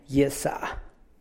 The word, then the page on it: gyia nub: gyìa’ nuub